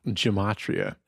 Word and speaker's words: gematria: Or sorry, the gematria. – Gematria. – As he pronounced it. I think it's gematria.